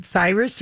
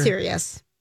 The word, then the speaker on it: Sirius: Is it Cyrus or Sirius? – It's Sirius. – It's Sirius. – No, it's S-I. It's Sirius. Oh. It's like Sirius Radio.